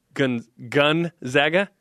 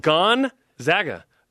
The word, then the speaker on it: Gonzaga: Gun Gonzaga. – No. It's not a gun. Gone. It's Gonzaga. Gonzaga.